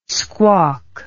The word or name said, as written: squawk